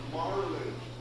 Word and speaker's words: Marlon: Marlin. – Okay. – Marvin. – Marvin. – Marvin. – No. Spell it, Mark. – M-A-R-L-O-N.